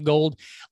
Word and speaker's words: Gould: It's pronounced Gold.